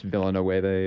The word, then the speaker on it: Villeneuve: Oh, Denis Villeneuve, or how do you pronounce that name? – Villeneuve.